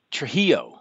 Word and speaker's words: Trujillo: and I was going to get Nick Menza to play drums and Robert Trujillo. Is that how you pronounce it, the Metallica guy? – Trujillo. Hello? Robert Trujillo.